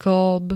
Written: kĺb

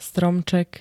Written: stromček

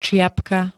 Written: ciapka

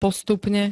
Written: postupne

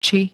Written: či

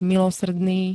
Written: milosrdný

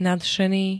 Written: nadšený

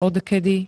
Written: odkedy

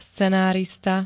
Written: scenárista

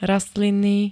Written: rastlinný

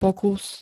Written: pokus

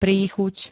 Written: príchuť

